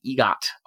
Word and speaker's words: EGOT: And she is one of only 15 people to have won what is called the EGOT, or I've also heard it just pronounced ego with a silent T, which is an Emmy, Grammy, Oscar, and Tony Award.